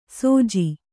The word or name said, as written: ಸೋಜಿ